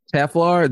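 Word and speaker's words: Telfar: What's the Tel? Was it Telflair? Is that how is that brand pronounced? – Teflar.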